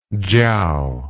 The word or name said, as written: chiao